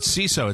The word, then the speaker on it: Seeso: CISO, it's spelled S-E-E-S-O.